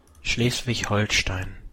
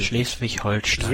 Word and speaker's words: Schleswig-Holstein: Come si pronuncia? – Schleswig-Holstein. – Hai sentito? – Schleswig-Holstein.